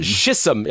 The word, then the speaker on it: Chisholm: Han är Chisholm. Chisholm, jag tror att det ska uttalas så i alla fall. C-H-I.